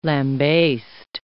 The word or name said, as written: lambast